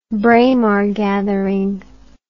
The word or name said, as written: Braemar gathering